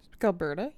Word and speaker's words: Gilberta: Later that month, on January 31st, she decides to go to a party at her friend, I can't say her name, Gilberte? – Okay. – Gilberta? – Gilberta, yeah. – She goes to her friend's house for, like, a little, it's like a, like a dinner party.